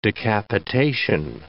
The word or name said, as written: decapitation